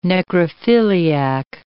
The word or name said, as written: necrophiliac